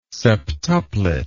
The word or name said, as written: septuplet